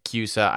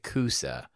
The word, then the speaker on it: CUSA: I will say CUSA. I promise not to try and say CUSA. There's a town in Oregon called Coos Bay. It's spelled COOS.